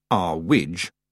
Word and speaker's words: Earwig: And Mrs. Earwig, pronounced Arwidge, at least by Mrs. Earwig,